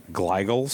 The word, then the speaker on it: glial: I'm going to totally mispronounce this word, but I think it's called glial cells. – Glial, yeah. – Glial, right? Then